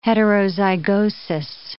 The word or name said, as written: heterozygosis